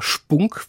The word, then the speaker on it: Spunk: Und mit dem Gedanken an Ihre internationale Literatur habe ich ihn instinktiv Englisch ausgesprochen. Spunk steht für Mumm, Courage, aber auch für einen vulgären Ausdruck. Nicht ganz falsche Fährte, kommen wir noch drauf. Aber es heißt klar Spunk.